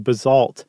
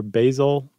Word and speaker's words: basalt: Which I think in the history of Stuff You Should Know, the 13-year history, it's the first time I said it correctly right off the bat. – Instead of what? What did we used to say? – Basalt or basil, basalt.